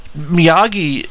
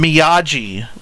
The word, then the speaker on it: Miyagi: And then Daniel calls him Miyagi, which is really weird, because, I can't imagine him getting it wrong. It's a very hard G sound. It's not Joe Miyagio, you big lug.